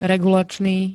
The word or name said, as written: regulačný